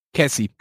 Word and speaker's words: Kessy: Cassie, ich hoffe, ich spreche es nicht falsch aus, K-E-S-S-Y, ja, wie würdest du es aussprechen? – Cassie.